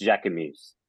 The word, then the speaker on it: Jacquemus: It's Jacquemus, but I don't want to sound like a tool, so I say Jacquemus. – Jacquemus. – Yeah, that's how you say it.